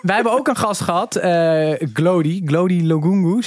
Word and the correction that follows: Lugungu: zeg ik dat goed, Tim? Nee, Lugungu. Lugungu, eh, de, de cabaretier van Comedy Train.